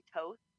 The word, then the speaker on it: Toth: It's the bane of my existence because it's actually Toth, rhymes with both.